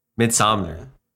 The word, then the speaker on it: Midsommar: Midsommar. – Yeah, there's no N, so it's just Midsommar.